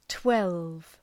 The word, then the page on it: twelve: twelv